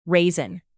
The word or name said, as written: raisin